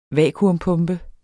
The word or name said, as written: vacuum